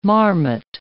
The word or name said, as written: marmot